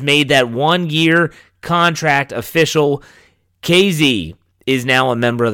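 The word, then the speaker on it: Kazee: I said Kazi on Monday. I know that Jeffrey Benedict made that mistake. He's the one that told me it's KZ is how you say the last name.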